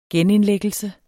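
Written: genindlæggelse

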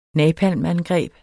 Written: napalmangreb

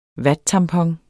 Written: vattampon

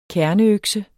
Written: kerneøkse

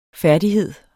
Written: færdighed

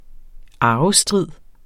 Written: arvestrid

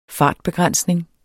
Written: fartbegrænsning

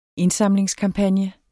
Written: indsamlingskampagne

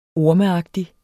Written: ormeagtig

